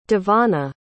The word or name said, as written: devonna